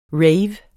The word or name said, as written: rave